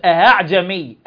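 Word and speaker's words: أأعجمي: أهاعجمي أهاعجمي، تنطق الهمزة الثانية بينها وبين الألف لأن حركتها الفتح، فهي لا همزة خالصة ولا ألف خالصة، وهي الكلمة الوحيدة التي سهلها حفص وجها واحدا أهاعجمي.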